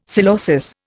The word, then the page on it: psilosis: Indian